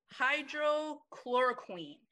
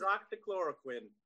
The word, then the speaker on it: hydroxychloroquine: It's called hydrochloroquine. And it's hydroxychloroquine.